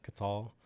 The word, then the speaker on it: Quetzal: I think I pronounced that right. Quetzal. – Sure. – Yep. Yeah. We believe you.